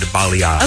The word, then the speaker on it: balayage: I've looked it up on several YouTube videos, and a few hairdressers said balayage.